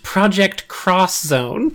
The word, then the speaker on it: Project X Zone: Project X Zone. – That I know about only because of two characters. – Excuse me, actually, you are wrong about one thing. It's pronounced Project Cross Zone.